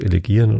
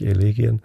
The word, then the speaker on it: Elegien: Also Duineser Elegien habe ich mittlerweile verstanden, dass ich sogar Elegien falsch ausgesprochen habe. Das heißt Elegien und nicht Elegien.